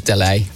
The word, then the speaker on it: Dalet: It's actually French, so everyone called it Dalet. This radio software. – Dalet. – Probably actually pronounced Delay.